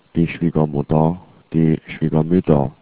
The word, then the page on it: schwiegermutter: german